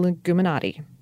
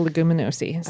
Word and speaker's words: Leguminosae: Not the Leguminati. The Leguminosae. Sorry, I wanted her so badly to say that.